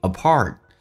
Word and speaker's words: apart: It's not being apart. Apart，来看一下，这个发音不是 R，是有点趋向于发澳大利亚的 O 这个发音。在这个基础上卷舌头，因为你无法张到最大，然后卷舌头，叫 R。 Apart，分开，being apart， while being love，当你在恋爱当中。